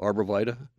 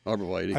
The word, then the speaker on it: arborvitae: How'd you say that? Did I say it wrong? – Arborvita. – Arborvita. – Arborvita. – I was saying arborvitae. – Arborvitae. – I've heard it said. – Tomato, tomato. – Northwest. Tomato, tomato. Yep.